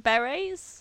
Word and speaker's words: beret: No, it's beret, like a hat.